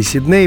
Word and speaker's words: Сидней: А его и нет. Потому что есть два варианта, Сидней и Сидней, как это часто бывает с иноязычными названиями, конкурирует ударение, соответствующее языку источнику, и ударение, которое от него отличается, но сложилось уже в русском языке. А Сидней и Сидней словари фиксируют оба варианта, а при этом, э, например, вот словарь Агенко, собственные имена в русском языке, адресованный работникам эфира – на первое место ставит Сидней.